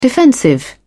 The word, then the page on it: defensive: British English